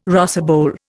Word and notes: rosebowl: Indian

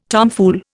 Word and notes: tomfool: Indian

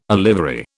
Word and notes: olivary: British English

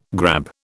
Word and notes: grab: British English

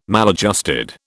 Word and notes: maladjusted: British English

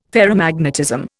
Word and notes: ferromagnetism: Indian

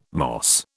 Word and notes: moss: British English

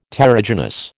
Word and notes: terrigenous: American English

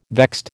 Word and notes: vexed: American English

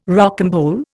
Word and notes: rocambole: Indian

